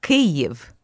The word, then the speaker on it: Kyiv: Yes, I was born and raised in Kiev, and I pronounce it in the Russian way, not in the Ukrainian way. – Okay. – Because Kiev is the Russian way, and Kyiv is actually the Ukrainian way, and somehow in America it got morphed into something third, which is foreign to me.